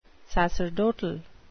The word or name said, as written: sacerdotal